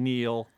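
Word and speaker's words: kneel: W and Neil. – Okay. – The K, which was silent until I spelled it earlier in the clue.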